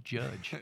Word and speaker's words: Judge: Rhymes with fudge. Judge.